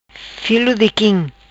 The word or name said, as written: Filo di Quim